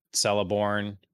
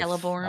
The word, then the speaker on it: Celeborn: Celeborn, that's how you say his name. The elf. – Celeborn.